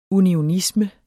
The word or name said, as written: unionisme